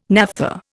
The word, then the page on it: naphtha: Indian